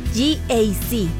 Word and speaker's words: GAC: No se dice GAC. Se dice GAC. Nuevamente, GAC.